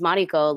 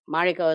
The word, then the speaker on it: Mariko: Like my name is Mariko. Let me just claim it and I'll teach people how to say it right. – Yes. – And so now it's like Mariko is like everywhere and people are learning and I'll correct them.